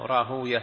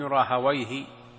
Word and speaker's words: راهويه: وابن راهوية أهل الحديث ينطقونه بهذا النحو: راهوية، سيبوية، زنجوية. وأهل اللغة: ابن راهويه، زنجويه، سيبويه، وهكذا، لأنها من أسماء الأعاجم، أسماء الفرس.